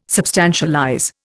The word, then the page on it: substantialize: Indian